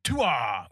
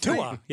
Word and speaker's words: Tua: I think you're mispronouncing it. It's Tua. – Okay. – Is that right? – Tua, yes.